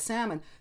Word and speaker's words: salmon: A lot of times the silent L, I'm going to use the L because we're talking about salmon, some people actually pronounce it now. It's, I mean, with the addition, it's like it's become an accepted, I mean, some dictionaries don't list it at all, but many do now,